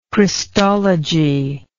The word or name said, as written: Christologist